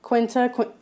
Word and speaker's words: Quinta: But yeah, so just to name some of the winners, I think Quinta, am I saying that right? – Yeah.